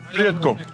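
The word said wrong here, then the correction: Prijatno: Prijetno. Yeah. – That's perfect.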